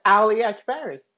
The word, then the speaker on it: Ali Akbari: Yeah, it's Ali Akbari.